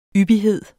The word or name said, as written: yppighed